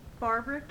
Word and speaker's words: Barbara: Barbara K. Harp, B-A-R-B-A-R-A, the initial K, H-A-R-P.